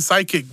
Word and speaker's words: psychic: It's like I'm fucking psychic, bro. I'm psychic with a P, but the P is silent for some... Why is there a P in psychic anyway?